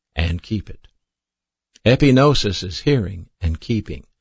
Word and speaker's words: epignosis: One of those words is spelled G-N-O-S-I-S, and it's pronounced gnosis. The other word is spelled E-P-I-G-N-O-S-I-S. That word is pronounced epignosis.